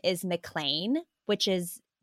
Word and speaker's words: McLean: And I'm really glad I watched it because it's spelled, looks like McLean, like L-E-A-N. Uh-huh. But they pronounce it McLean, just like John McLean.